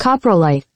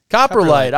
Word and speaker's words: coprolite: Coprolite. – Coprolite. I was I was pretty much correct. Maybe a, a you know I might have put the right emphasis on the wrong syllable, but uh, I was pretty much right.